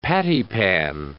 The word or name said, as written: pattypan